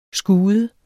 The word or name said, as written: skude